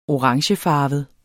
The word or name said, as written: orangefarvet